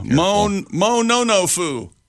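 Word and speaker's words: mononofu: Mo-no-no-fu.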